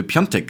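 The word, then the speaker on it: Piątek: Und da steht halt Piatek auf dem Trikot. Und wir wurden wirklich wüst beschimpft dafür, dass wir ihn richtig ausgesprochen haben, weil wir Piontek gesagt haben.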